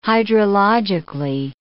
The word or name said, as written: hydrologically